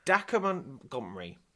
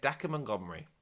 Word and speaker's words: Dacre Montgomery: Dacre Montgomery. I'm going to say that again. Dacre Montgomery.